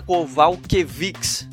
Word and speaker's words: Kowalkiewicz: Adriano, agora falando da co-luta principal do evento, a gente vai ter aí a Claudinha Gadelha contra a Carolina Kowalkiewicz. Falei certo? – Acho que sim, acho que falou certinho.